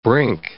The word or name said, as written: brink